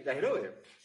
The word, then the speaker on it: Yajirobe: Yajirobe, o como se pronuncia. Sí, sí, Yajirobe.